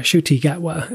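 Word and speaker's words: Ncuti Gatwa: And the 15th Doctor, Shuti Gatwa,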